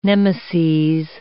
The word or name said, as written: nemeses